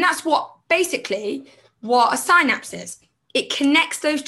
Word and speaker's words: synapse: And I just realized that I actually just said that wrong. It's a synapse.